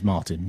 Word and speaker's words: Martin: It is Martin, yes.